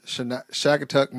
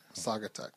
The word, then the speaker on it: Saugatuck: Last week, I found myself having sex in the woods with different guys in Shagatuck, Michigan. Or Shagatall. Is that how you say it? – Shagatuck, Michigan. It was one of the most exhilarating experiences of my life. – Saugatuck.